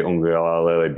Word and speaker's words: Uiagalelei: Uiagalelei being able to capitalize them on them, just because he hasn't done it as much as Trevor Lawrence has.